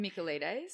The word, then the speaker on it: Michaelides: So the book is Silent Patient by Alex Michaelides. Sorry if I pronounced that wrong. – You definitely pronounced that wrong. – Michaelides. – Yeah, I think that's right.